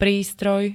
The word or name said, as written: prístroj